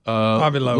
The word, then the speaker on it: Avi Loeb: How would you say that, with that culture? – AVI? – Avi. L-O-E-B. – L-O-E-B. Avi Loeb. Lisa Loeb. That's how she spells her name. So Avi Loeb.